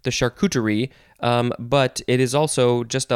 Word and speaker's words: charcuterie: Next is charcuterie. This is kind of, you know, it's a French word. It doesn't necessarily look like it's pronounced. C-H-A-R-C-U-T-E-R-I-E, charcuterie.